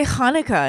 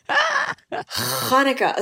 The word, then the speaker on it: Hanukkah: You just heard me say Hanukkah, and he was like, "Oh, okay." Because I said it correctly. – First of all, I am so impressed. Hanukkah.